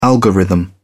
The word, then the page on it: algorithm: British English